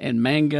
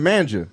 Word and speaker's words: manga: It's a series of like books and manga and a whole bunch of different stories. – Is that how you say it? – It's manga. – Manga? – I've been saying manja.